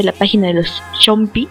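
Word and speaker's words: Soompi: Sí, no se olviden que las votaciones para los Chompy, no sé por qué dije Zompy en las rápidas, pero se pronuncia algo así como Chompy.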